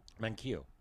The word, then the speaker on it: Manquillo: Is that how you pronounce that? Manquillo? – Manquillo.